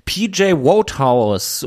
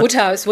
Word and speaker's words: Wodehouse: PJ Wodehouse und das Buch heißt Ride Ho Jeeves und äh, PJ Wodehouse habe ich mir immer mal Woodhouse, Woodhouse wird da ausgesprochen.